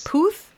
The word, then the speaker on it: Puth: I think it's Puth. – Charlie Puth. Puth. Like Booth. – That's what it is? – Puth?